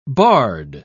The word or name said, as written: bard